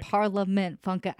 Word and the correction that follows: Parliament Funkadelic: George Clinton and the Parliament Funkadelic.